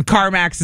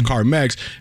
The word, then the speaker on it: Carmex: CarMax is an actual business as well, but that's not out of Milwaukee. – Right. No, yeah. I always pronounced it CarMax, even though it's CarMex.